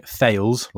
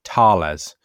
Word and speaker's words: Thales: So, instead of it being Thales, like I pronounced it, it's actually Thales. And that's because it turns out that Thales is a French defense contractor, and you know, you just pronounce things differently in French than in English.